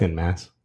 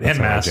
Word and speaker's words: en masse: How do you say that? – En masse. – Is it? Well, all right, en masse. – That's how I generally say it.